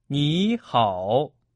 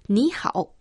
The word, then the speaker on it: 你好: A pronúncia de 你é parecida com a palavra em inglês 你e 好 similar ao how.Raimundo, você sabe o que responder quando alguém dizer ni hao para você? – Bem, deixe-me ver.